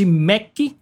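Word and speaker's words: Méqui: de chamar de Mac, né, que é como a gente pronuncia.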